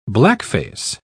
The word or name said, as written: blackface